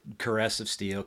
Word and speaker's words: Caress of Steel: Is that how you say it? I said it wrong. – Yeah. Caress of Steel. – Caress. – Of Steel. – Okay. – I've heard it pronounced different ways. – It's like Pert and Peart.